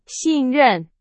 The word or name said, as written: 信任